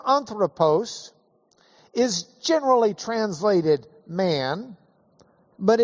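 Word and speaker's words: Anthropos (ἄνθρωπος): This is a Greek word, A-N-T-H-R, long O, P-O-S. In English letters, that would be an A-N-T-H-R, long O, P-O-S. Anthropos. Anthropos. Say it with me. Anthropos.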